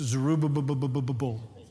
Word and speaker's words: Zerubbabel: It's hard to pronounce. It's Zerubbabel. It's kind of like banana na na na na na na na na na na. Zerubbabel did.